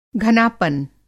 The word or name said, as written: घनापन